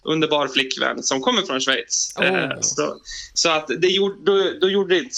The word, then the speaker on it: Schweiz: Schweiz. – Ja, Gustav Fridolin hade ju lite problem med det för några år sedan, men det stämmer. Schweiz.